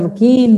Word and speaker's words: Luchini: Ah, Samanta Luquini, Samanta Luquini, tem a página da empresa, tem a minha no Instagram, Samanta Luquini, no Facebook, Samanta Luquini Desenvolvimento Humano. É o nome mesmo, facinho de me achar. – Samanta Luquini, Luquini se escreve L-U-C-H-I-N-I. Isso, isso mesmo.